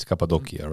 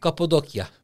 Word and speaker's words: Cappadocia: It's Cappadocia, right? – Cappadocia.